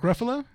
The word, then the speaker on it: Ruffalo: How do you pronounce his name? Mark. Mm-hmm. Mark Ruffalo. – Mark Ruffalo. Oh yeah.